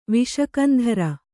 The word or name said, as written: ವಿಷಕಂಧರ